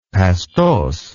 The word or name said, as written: pastose